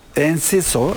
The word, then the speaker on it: Enciso: Enkuzio'da dünya... – Enciso. – Özür dilerim telaffuz için.